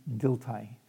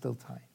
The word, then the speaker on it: Dilthey: When Buber went to the university, he studied with a given professor named Diltai, D-I-L-T-H-E-Y, but pronounced Diltai.